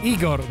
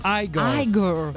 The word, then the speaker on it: Igor: Oh, non si dice Igor, ma Igor.